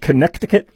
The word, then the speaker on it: Connecticut: Senator Chris Murphy, who's a Democrat out of Connecticut, where the middle C is silent,